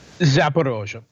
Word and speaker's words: Zaporozhye: Zaporozhye.